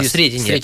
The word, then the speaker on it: Сретение: Он называется «Сретение Господне». Так я ударение поставил? – Да, да, «Сретение».